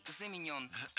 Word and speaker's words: заменен: Вторая ошибка – заменен. Это краткое причастие, и ударение в нем такое же, что и в полном. Замененный – заменен.